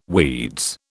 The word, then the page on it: weeds: British English